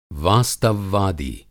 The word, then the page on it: वास्तववादी: Hindi